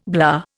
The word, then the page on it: blah: Indian